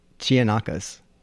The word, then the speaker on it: Chianakas: And it's a signed postcard for the Horror Book of the Year author, Joe, and I hope I pronounce this right, Chinajas? – That's a K. Chianakas, I would say.